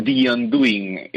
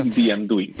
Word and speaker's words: The Undoing: The Undoing, el deshacerse, vamos a llamarlo. – Eso. – The Undoing.